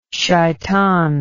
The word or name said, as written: shaytan